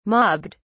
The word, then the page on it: mobbed: British English